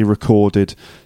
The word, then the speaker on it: record: Anyway, the way it's pronounced is record, on the record. So the record is the noun.